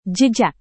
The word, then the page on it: jejak: Indonesian